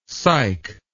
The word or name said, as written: syke